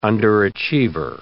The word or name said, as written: underachiever